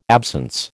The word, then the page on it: absence: American English